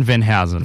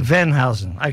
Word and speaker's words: Venhuizen: Now we've been talking to Aaron Venshuizen. That they have pronounced it right? – Close. Aaron Venhuizen. – Venhuizen, okay. Which is a Dutch name, but a very nice 23-year-old who...